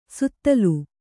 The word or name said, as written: ಸುತ್ತಲು